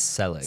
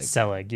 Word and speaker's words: Selig: He's the guy, I think I'm pronouncing that right. – It's Selig. – Selig, yeah, I think you are right.